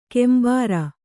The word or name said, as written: ಕೆಂಬಾರ